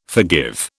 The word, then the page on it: forgive: British English